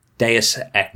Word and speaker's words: Deus Ex: King Trilo chose Deus Ex, or whatever, however you pronounce that, actually. Deus Ex? Is that how you say it? – Deus Ex. – You say Deus? Okay. Deus Ex.